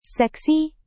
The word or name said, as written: seksi